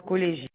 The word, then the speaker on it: Collégien: Alors, CS 40 779 à la ligne 77 615 collégien, comme le nom se prononce, collégien. Collégien, oui, je confirme.